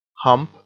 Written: hump

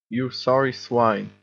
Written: you sorry swine

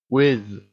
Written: whiz

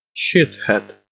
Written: shit-head

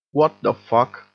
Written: what the fuck

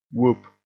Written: whoop

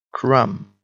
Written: crumb

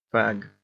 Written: fag

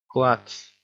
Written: klutz

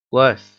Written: les

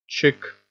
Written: chick

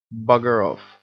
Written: bugger off

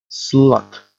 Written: slut

